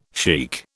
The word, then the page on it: chic: British English